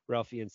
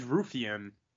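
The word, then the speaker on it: Raufeon: Ralphian Stotts. Is it Ralphian? I always say his name wrong. – I think it's Ruffian.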